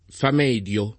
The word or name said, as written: famedio